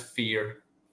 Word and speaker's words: Fior: But we're not Italian, so it's a more harsh sounding word. It's pronounced fear.